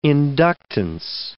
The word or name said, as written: inductance